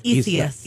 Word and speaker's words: Isaias: It's Isaias.